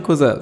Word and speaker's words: Cousin: Wie schreibt man Cousin? Wie, schreibt, wie, spricht man das aus? Sagt man Cousin? – Ja. Wie die Küche, Cuisine. Oder Cousin. Cousin. Cousin.